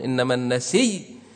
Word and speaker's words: النسيء: فعيل، فالياء زائدة وقفت عليها تقول نسيء، أبدل الهمزة إلى ياء صارت نسي، ثم أدغم الياء في الياء إنما النسي، أنت واقف قف عليها بالسكون إنما النسي، لكن يجوز لك الروم والإشمام. الإشمام إنما النسي، يعني تضم شفتين بعد النطق بالسكون إنما النسي، والإشمام والروم إنما النسي، إنما النسي، النسي.